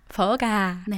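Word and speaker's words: gà: 저 또이 퍼가 버가. – 네. – 버가. 가 하면은 지하철역이 돼버려가지고. – 아, 그러네요. – 네, 버가. 발음이 좀 중요합니다.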